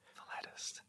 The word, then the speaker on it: philatelist: Because he is a philatelist or philatelist? Is that what it is? – How it's pronounced? Yeah, P-H-I-L-A-T-I-S-T. – Philatelist.